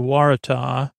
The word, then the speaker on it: Waratah: Again, their state flower is the Waratah. Almost rhymes with Murtaugh.